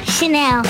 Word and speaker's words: Chanel: Chanel. – Oh, she knows how to pronounce it. It's not Chanel.